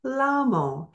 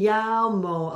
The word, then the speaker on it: llamo: This word is a little tricky only because of the double L. So, as English speakers, we want to say that as a L sound, lamo. But you don't want to say la-la-lamo. You want to pronounce the double L always in the Spanish language as a Y sound.